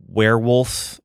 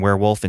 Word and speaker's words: Weirwulf: Now, by that time, they had changed the name to Baron Werewolf, but it's spelled W-E-I-R-W-U-L-F, Baron Werewolf's Haunted Library.